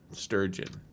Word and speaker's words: Sturgeon: S-T-U-R-G-E-N, like surgeon with a T. Sturgeon.